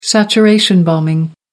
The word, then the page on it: saturation bombing: American English